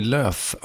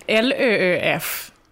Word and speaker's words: Lööf: Jag hörde en intervju med dig där var någon som kallade dig för Annie Lööf också. Är det jobbigt? – Ja, alltså L-Ö-Ö-F ska ju egentligen uttalas Lööf, men jag säger ju Lööf.